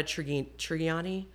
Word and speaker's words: Trigiani: Adriana Trigiani.